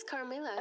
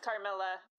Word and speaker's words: Carmilla: Yeah, I don't ever know if I'm supposed to pronounce those double L's. – Yeah, I think it's Carmilla. – I think it's Carmilla, yeah.